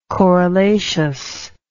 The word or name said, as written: corollaceous